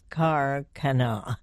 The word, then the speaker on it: Khairkhana: Talking about a new book titled The Dressmaker of Karkana. And that, by the way, is spelled K-H-A-I-R-K-H-A-N-A, but pronounced as though it were C-A-R and then Kana.